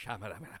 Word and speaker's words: Shyamalan: Shyamalan. Racist. Shyamalan.